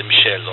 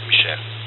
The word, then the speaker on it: Michel: The name is Michel, or Mike, but in French it's Michel.